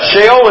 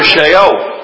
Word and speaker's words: Sheol: We go to Sheol, or Sheol as I've heard it pronounced. However you want to pronounce it, I don't care.